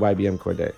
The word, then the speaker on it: YBN Cordae: I'm going to go with YBM Cordae. – Okay. – And I'm not sure if I'm saying his name correctly. – Yeah, you are.